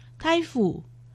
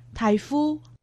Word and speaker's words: 大湖: 苗栗县大湖乡客家话四线腔发音台府，海陆腔发音台夫。